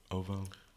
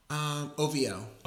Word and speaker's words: OVO: Uvu Sound Radio. So I pronounced that right, right? Uvu? Ovo? – OVO.